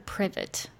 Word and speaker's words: Privet: Is this Private Drive or Privet Drive? – I've always heard Privet. So that's yeah, me too.